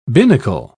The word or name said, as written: binnacle